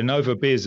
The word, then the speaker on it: Innova Biz: I always say this wrong, Innova, Innova Buzz, right? I get it. – Yeah, Innova Biz is the business. Innova Biz. Okay, Innova Biz at Innova Biz was just like really beautiful.